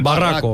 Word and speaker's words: Barraco: El Barraco.